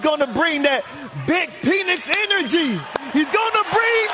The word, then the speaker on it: Penix: It's been in the pronunciation guide. It's Penix.